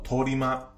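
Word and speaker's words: 通り魔: 无差别杀人这个词最早起源于日本，日文读作 “torima”，直接翻译过来就是“ 街头恶魔” 的意思。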